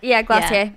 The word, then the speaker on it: Glossier: Jordan was like, is it French? Like, it says Glossier. And I'm like, I'm sure it's Glossier. – Glossier. – Yeah. I think it is French.